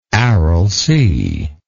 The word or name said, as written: Aral Sea